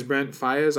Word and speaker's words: Brent Faiyaz: Uh, the next song is Brent Faiyaz.